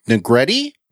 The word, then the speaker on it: Negrete: Gimple said several months back, it is written by Matthew Negrete. It rhymes with spaghetti.